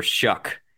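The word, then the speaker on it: Shough: Shuck is his last name rhymes with suck.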